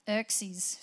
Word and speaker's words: Xerxes: Xerxes, spelt with an X, but I think you pronounce it with a Z.